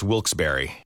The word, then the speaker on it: Wilkes-Barre: Now, I had heard that it was supposed to be pronounced Wilkes-Barre.